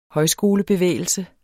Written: højskolebevægelse

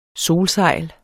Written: solsejl

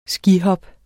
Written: skihop

